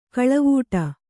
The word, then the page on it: ಕಳವೂಟ: kaḷavūṭa